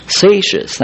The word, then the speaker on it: Seixas: Rabbi Seixas, is that how you pronounce that name? Seixas, thank you, Seixas at Kirtland, from whom Joseph Smith learned his Hebrew.